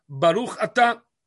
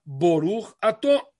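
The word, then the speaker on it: Baruch Atah (ברוך אתה): Alguns preferiam a pronúncia sefaradita, dizendo Baruch Atah. Alguns preferiram a pronúncia ashkenazi, dizendo Boruch Ato. Após longas discussões, o comitê linguístico decidiu adotar a pronúncia sefaradita.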